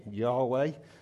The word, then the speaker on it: Yahweh: That's how we get round to pronouncing it, Yahweh, in nice flat English.